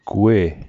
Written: gwe'

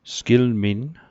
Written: sgilminn

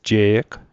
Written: je'g

